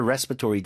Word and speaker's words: respiratory: 미국 발음은 respiratory고 영어 발음은 Respiratory. – 그렇죠. respiratory. 아예 다른 단어처럼 들립니다.